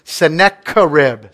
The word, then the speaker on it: Sennacherib: There's one word in there that you might trip up on, but I looked it up and the official Google translations or pronunciation says Sennacherib, not Sennacherib, Sennacherib.